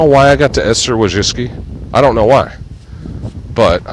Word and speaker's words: Wojcicki: And like, man, I didn't like seeing Susan Wojcicki, or it rhymes with whiskey. Wojcicki, according to Charlie Rose.